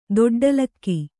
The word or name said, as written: ದೊಡ್ಡಲಕ್ಕಿ